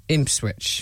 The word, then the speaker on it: Ipswich: Ipswich.